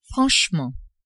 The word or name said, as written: franchement